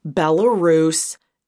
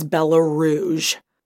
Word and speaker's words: Belarus: Now, Nicole is doing the right thing by pressuring for details for just a moment until she pronounced Belarus as Belarouge.